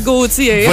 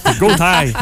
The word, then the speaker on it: Gotye: G-O-T-Y-E. Mm-hmm. Comme ça se prononce. – Comme ça se prononce. – Oui, moi je disais Gauthier avant, parce que c'est assez spécial que ça se prononce Gauthier.